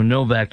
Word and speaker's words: Nowak: Meanwhile, the guy that that was a Sikh murdered Henry Nowak, or Novak, depending on your pronunciation,